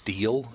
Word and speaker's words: Stiel: Your last name is pronounced Stiel, is that correct? – Yes. – Violet Ramis Stiel.